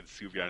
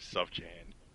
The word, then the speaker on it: Sufjan: It took me years before I figured out that it was actually pronounced fucking Sufjan instead of Sufjan.